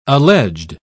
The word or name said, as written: alleged